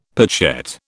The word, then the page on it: pochette: British English